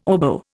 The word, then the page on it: oboe: Indian